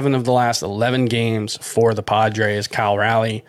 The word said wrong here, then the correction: Cal Raleigh: Cal Raleigh, excuse me, I don't know why I say it like Kyle, but Cal Raleigh, one for four with his ninth home run.